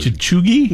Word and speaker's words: cheugy: It's spelled C-H-E-U-G-Y, which is absurd. – Chuggy? – Yes.